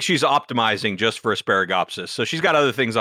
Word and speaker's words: Asparagopsis: Is it Asparagopsis? Am I saying that right? Asparagopsis? – You are. And we have actually invented our own variety of Asparagopsis called Brominata.